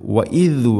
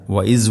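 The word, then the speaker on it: വഇദ് (وإذ): വഇദ് വഅദ്ന ഇവിടെ എല്ലാ ക്ലാസ്സിലും പറയാറുള്ളത് പോലെ തന്നെ ദാലിൻ്റെ ഉച്ചാരണം ദ എന്നോ ദ എന്നോ ആവരുത്. വഇദ് വഅദ്ന, വഇസ് വഅദ്ന അല്ല, വഇദ് വഅദ്ന.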